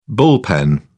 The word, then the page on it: bullpen: British English